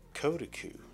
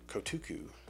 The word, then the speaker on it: Kotuku: It is the Kotuku, or Kotuku, and it is spelled K-O-T-U-K-U.